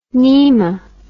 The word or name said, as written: nema